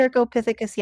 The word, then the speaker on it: Cercopithecus: Anyways, scientific name, Cercopithecus neglectus. Can I say that right? – Yeah. – Did I say that right? – Cercopithecus, yeah, neglectus. Okay. You're right.